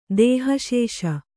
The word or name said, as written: ದೇಹಶೇಷ